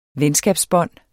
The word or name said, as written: venskabsbånd